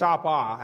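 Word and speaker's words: apa (ἀπό): But then you have this word apo. It's apa, actually. It's short O.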